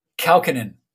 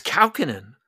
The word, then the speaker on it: Kaukonen: How do you say it? – Kaukonen. – It's Kaukonen. – Yeah, Kaukonen.